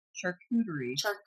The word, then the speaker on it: charcuterie: This is the charcuterie board. – Yes, of plants. I can never say that word. – I love that. Oh, it's okay. It's a very interesting word. Charcuterie.